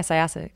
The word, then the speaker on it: Syosset: Syosset, New York? – Yeah. – Is that how you pronounce it? – Yeah, Syosset, good job.